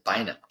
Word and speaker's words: Bynum: But it's going to be defensive back Cameron Bynum, if I'm saying that right. – Bynum.